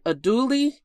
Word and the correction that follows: andouille: sausage. – Andouille.